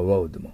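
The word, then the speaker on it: rode: 道路の方のロードは、うの口ですから、ベロが下、ロード。で、ライド、乗るの過去形のロードも、発音同じだけど、ややこしいですよ。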